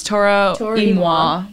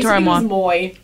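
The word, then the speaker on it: Toro y Moi: I think it's Toro Imoa. Yeah. I always say Toro Imoa.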